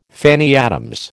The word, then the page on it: Fanny Adams: American English